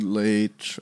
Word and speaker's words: Leitch: Like, uh, granted that David, how do you say his last name? Leach or Leitch? David Leach. Oh, Leach.